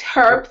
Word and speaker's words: Herb: Herb, thank you. Pronounce the H.